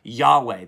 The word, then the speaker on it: Yahweh: Or sometimes you might have heard it said Yahweh. That's probably how they actually pronounced it.